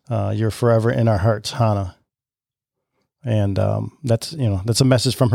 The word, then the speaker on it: Hana: So it's pronounced Hana, and Hana's name in Japanese means flower.